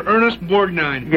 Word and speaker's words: Ernest Borgnine: You wrote another play called My Marriage to Ernest Borgnine. – Yes, I did. – Did I pronounce that right? – Yes, you did.